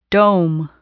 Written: dome

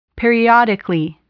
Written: periodically

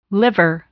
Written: liver

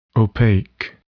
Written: opaque

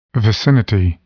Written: vicinity

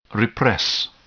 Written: repress

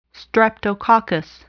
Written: Streptococcus

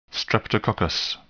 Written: Streptococcus

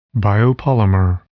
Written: biopolymer

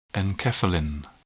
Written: enkephalin

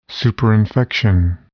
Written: superinfection

